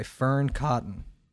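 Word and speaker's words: Fearne Cotton: Could you be more authentically you by Fern Cotton? Is that pronounced correctly? – Yeah. Nailed it.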